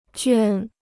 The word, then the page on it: 圈: juān